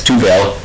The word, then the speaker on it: Tevell: My name is Walter Tuvel. Yes, Tuvel. I'll spell it. T-E-V-E-L-L.